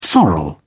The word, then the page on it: sorrel: British English